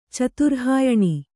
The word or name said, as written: ಚತುರ್ಹಾಯಣಿ